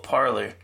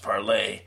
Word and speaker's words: Parler: So, in case you hadn't heard, the CEO of Parler, or actually, as it's supposed to be pronounced, Parlay, says he has been fired by the board.